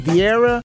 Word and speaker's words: Vieira: Is it Vieira? – Yeah.